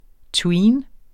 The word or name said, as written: tween